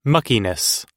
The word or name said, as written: muckiness